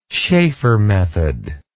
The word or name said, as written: schafer method